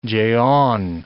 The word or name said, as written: jeon